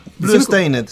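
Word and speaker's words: Bloodstained: Otra noticia un poco más triste, Blues Stein, no sé cómo se pronuncia, Blues Stainet Ritter of the Night. Blue Stained, sí. ¿Se pronuncia así? Sí el ambicioso proyecto de eh,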